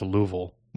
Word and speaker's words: Louisville: I'm seeing that it says it's headquartered in Louisville, Kentucky. – Don't tell a Kentuckian that because they'll correct you and say it's pronounced Louisville.